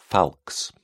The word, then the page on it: falx: British English